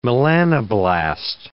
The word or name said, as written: melanoblast